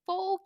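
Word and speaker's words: phở: This one is Pho Kim. And I was like, no, mom, it's pronounced pho.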